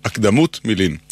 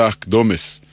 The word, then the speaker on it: הקדמות: אמרנו שהפיוט נקרא הקדמות, על שם מילת הפתיחה שלו, אבל כבר שמעתי מבטאים זאת הקדמות, כביכול צורת ריבוי. חלק מהטעות קשור לשיטת היידיש, שהרי היידיש הדגישה לא את העברה האחרונה, אלא את שלפני האחרונה. לא הקדמות, אלא הקדומס.